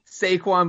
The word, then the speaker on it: Saquon: Saquon Barkley.